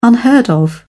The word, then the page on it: unheard of: British English